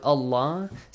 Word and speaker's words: Allah: If we got a fatha or a dhamma before the word Allah, so the letter lam in the word Allah will be pronounced with a heavy sound, with an a sound.